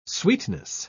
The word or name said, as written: sweetness